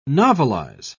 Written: novelize

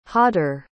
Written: jader

